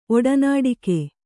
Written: ಒಡನಾಡಿಗ

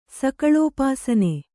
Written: ಸಕಲೋಪಾಸನೆ

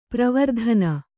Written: ಪರವರ್ತನ

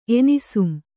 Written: ಎನಿಸುಂ

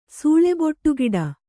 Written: ಸೂಳೆಬೊಟ್ಟುಗಿಡ

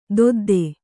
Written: ದೊದ್ದೆ

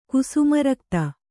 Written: ಕುಸುಮರಕ್ತ

